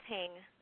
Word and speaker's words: Hang: That's Hing. – Hing. Hing, H-A-N-G with a, okay, got that.